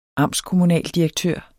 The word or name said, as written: amtskommunaldirektør